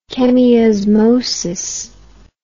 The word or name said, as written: chemiosmosis